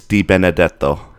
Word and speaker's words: Di Benedetto: My last name's Italian, and so often people do have a particularly difficult time pronouncing it, but it's very easy. It's just like it looks. It's Di Benedetto.